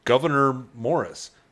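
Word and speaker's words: Gouverneur Morris: I believe the correct pronunciation of this is Governor Morris.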